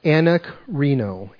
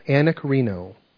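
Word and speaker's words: anakrino (ἀνακρίνω): I went to the original Greek here to understand what the original word was, and it's pronounced anakrino, anakrino, okay?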